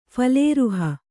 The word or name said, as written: ಫಲೇರುಹ